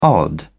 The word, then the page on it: odd: British English